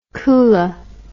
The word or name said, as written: kula